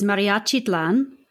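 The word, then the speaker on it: Mariachitlán: So the first piece we're going to listen to today is Mariachitlan. Did I pronounce that correctly? – Correct.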